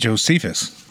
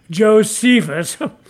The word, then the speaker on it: Josephus: You mean Josephus? – Oh, that's right. Yes. We did agree to use the less academic pronunciation. Yes, as you lay people call him, Josephus.